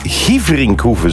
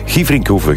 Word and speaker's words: Gieverinkhoeve: Benoit Dorné stuurt, hey Björn, de rally is in Gieverinkhoeve, zo spreek je het uit. Gieverinkhoeve, groetjes, de papa van Jules.